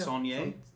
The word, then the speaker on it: Saulnier: Saulnier? Yeah. Anyway. – Is he French? – S-O-N-I-E-R? – No. S-A-U. Saul N-I-E-R.